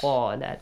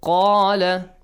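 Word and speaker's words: قال: So, قَا has a heavy sound. وَإِذْ قَالَ. Not وَإِذْ قَالَ. No. وَإِذْ قَالَ. If you pronounce it with قَالَ, it's kind of weird. It's not Arabic, is it? – No.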